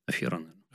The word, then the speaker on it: афера: Вот афера с задатком, допустим, да, или как правильно говорить, афера или афера? Афера.